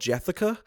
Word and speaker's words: Jethica: I actually did just catch up with a title that I missed at last year's Overlook called Jethica, which I am pronouncing correctly. It is right. – Jessica with a lisp.